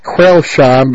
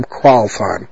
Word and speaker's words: Crailsheim: Well, stationed in Crailsheim, spelled Crailsheim. Pronounced, English Crailsheim, but, pronounced by them Crailsheim.